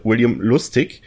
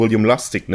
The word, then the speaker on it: William Lustig: Der Regisseur dieses Films, über den wir heute reden werden, der trägt den Namen, wenn wir ihn deutsch aussprechen, William Lustig. Ich werde die amerikanische Version bevorzugen und werde ihn William Lustig nennen.